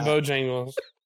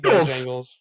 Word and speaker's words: Bojangles: It rhymes with low gangles. – That's right. – Oh, I am not eating dude, that. – He won't. – I am not. – He won't eat Bojangles.